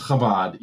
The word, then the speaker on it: Chabad: But this is even weirder because like it's a kind of like just real quick to check my pronunciation. It's Chabad, right? Not Chabad. – Yeah. – It's Chabad. Yeah.